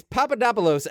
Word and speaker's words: Papadopoulos: up at it's Papadopoulos, and I know this, Maureen, because I spent an extensive amount of time on YouTube channels that just tell you how to pronounce things, which is a weird set of YouTube channels.